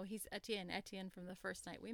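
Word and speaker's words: Étienne: I love how she breaks up his name in three syllables. Like she says on page 253, my feet trip in three syllables. Etienne, Etienne, Etienne.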